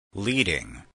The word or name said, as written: leading